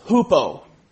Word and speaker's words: hupo: The first part of that word is hupo. Hupo. Or in English we pronounce it hypo.